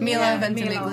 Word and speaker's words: Milo Ventimiglia: Milo Ventimiglia.